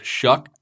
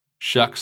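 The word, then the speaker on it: Sough: Tyler Shuck, who I've previously referred to as Tyler Shaw or Tyler Show, but in speaking with him, the way to pronounce it is Shuck. That's S-O-U-G-H, but it's pronounced Shuck somehow.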